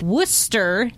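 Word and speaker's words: Wooster: Yes, that is the correct pronunciation. – Even though it's spelled wrong, W-O-O-S-T-E-R. I have called it Worcester. – A lot of people do. – For 20 years. – Like rooster.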